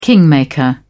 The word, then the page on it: kingmaker: British English